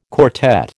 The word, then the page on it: quartet: American English